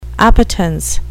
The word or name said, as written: appetence